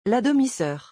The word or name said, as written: La demi soeur